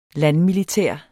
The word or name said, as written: landmilitær